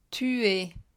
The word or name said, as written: tu es